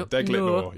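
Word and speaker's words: Deglet Noor: It's spelled deglet. – Yeah, deglet noir. Yeah. They are not the posher medjool variety. On the side of the packet, they are described as, quote, glove box deglet noir dates.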